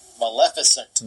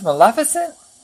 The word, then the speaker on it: Maleficent: Maleficent. – Maleficent? Seriously, it's Maleficent? – Maleficent.